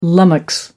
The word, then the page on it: lummox: American English